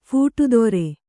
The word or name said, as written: ಫೂಟುದೊರೆ